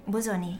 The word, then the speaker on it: Bosoni: Bosoni, yes, it's correct.